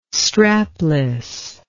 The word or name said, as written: strapless